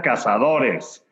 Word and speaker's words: Cazadores: Yes, We all, they always, how do you say tequila cazadores? And I say tequila cazadores.